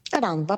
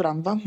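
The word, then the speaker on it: Rannvá: Jeszcze to A z kreseczką u góry, jak się wymawia? – Ranva, po prostu Ranva, dobrze powiedziałeś.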